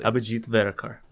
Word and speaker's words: Abhijit Verekar: Abhijit Verekar.